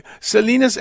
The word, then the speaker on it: Salinas: S-A-L-I-N-A-S. – Yes. – How do you... Salinas, it's Salinas, right? That's how they say it in California.